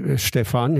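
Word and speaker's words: Stephan: Stefan eben mit PH und nicht mit F, ja.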